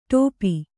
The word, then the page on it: ಟೋಪಿ: ṭōpi